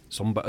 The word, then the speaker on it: Sombr: That's Somber. Somber. It's spelled S-O-M-B-R.